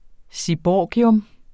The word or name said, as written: seaborgium